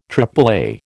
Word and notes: AAA: American English

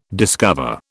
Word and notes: discover: British English

